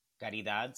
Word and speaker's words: Caridad: Caridad. C-A-R-I-D-A-D.